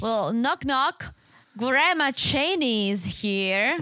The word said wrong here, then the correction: Shaney: You know her name is Shane, right? – It's not Cheney? – No, it's spelled as Shaney, but it's Shane.